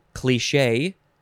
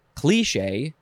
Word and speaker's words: cliché: You could say cliche, you could say cliche, or you could say cliche. You don't have the long E in there, cliche.